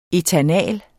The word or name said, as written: ætanal